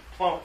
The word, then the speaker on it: Plone: Plone is a content management system. Plone. Sorry, my pronunciation. – I heard clone, but you mean Plone. Oh, Plone. – That's right.